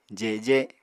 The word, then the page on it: jeje: Apatani